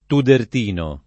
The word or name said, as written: tudertino